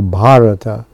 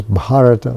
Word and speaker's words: Bharata: And also the land is Bharata, Varsha, with a long A. That's what happens. And Prabhupada says there's three Bharatas. And the other one is the son of Maharaj Dushanta. That's where the short A, the three, this other Bharata.